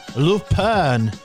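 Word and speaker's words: Lupin: Uh, un uh, franču krimināla gabals, kas jau ir pieminēts nereiz vien šajā raidījumā, Lupēna. Nu, tā tas jāizrunā. Bet raksta Lupina, raksta Lupina, izrunā Lupēna šorīt uz paplātes.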